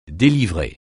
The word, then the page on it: délivrer: français